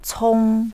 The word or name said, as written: cong